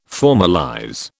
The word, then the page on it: formalize: British English